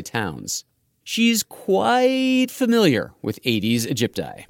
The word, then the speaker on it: Aedes aegypti: Leslie Vossall spends most of her time studying one mosquito species in particular, Aedes aegypti. That's two Latin words, Aedes, A-E-D-E-S, and aegypti, spelled like A-Egypt-I.